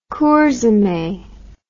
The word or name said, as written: Kurzeme